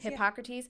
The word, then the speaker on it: Hippocrates: In fact, Socrates' own mother, who and he was Hippocrates, Hippo- Hippocrates, right? That's how you say that. The Hippocratic Oath comes from Hippocrates. – Hippocrates.